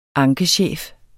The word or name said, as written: ankechef